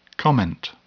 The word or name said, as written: comment